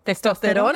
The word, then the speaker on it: testosterona: Testosterona. I think that's how you say it in Spanish, right? – Testosterone. I guess. Testosterona. Testosterona. – Testosterone in Spanish. – Why doesn't it talk? – Hey Siri, how do you say testosterone in Spanish? – Testosterona. Testosterona. There we go.